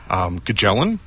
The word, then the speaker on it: Kachellan: And then, yes, the Kerosene Kites, Beth Kelly and Eric Kachellan will be here. Kachellan, I think he says he pronounces it kind of like cello.